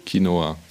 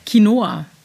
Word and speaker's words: Quinoa: Kin Quinoa Quinoa, wie wird denn das ausgesprochen? – Quinoa. – Quinoa. Wieder was gelernt.